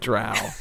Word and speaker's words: Drow: Drow rhymes with wow.